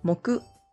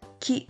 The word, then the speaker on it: 木: For Thursday, the two Japanese pronunciations are moku and ki. Moku can be used in mokuyobi, which is Thursday, and ki for ki no ue. The Chinese pronunciation is mu.